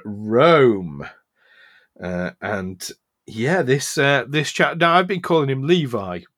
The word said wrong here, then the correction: Livy: which I think it's Livy.